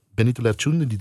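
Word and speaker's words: Benito Lertxundi: Benito Lerchundi, zeg ik het goed? Ja, heel goed. Benito Lerchundi, de, de Frank Sinatra van het Baskeland.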